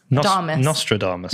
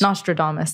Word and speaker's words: Nostradamus: I mean, it's so early for how I use, you know, I don't know how to say it exactly, Damus. Astrodamus. – Nostradamus. – Nostradamus, thank you.